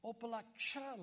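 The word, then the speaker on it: Opa-locka: It's Opa-laksha, like cha-cha-cha.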